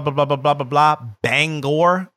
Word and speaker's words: Bangor: Local banger resident. local resident of Bangor, Pennsylvania. – That's how they say it? – No, but that's how people that don't know banger say it.